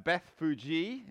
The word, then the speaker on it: Bethphage: Uh, Bethphage is how you pronounce it, if you didn't know, and Bethany at the Mount of Olives.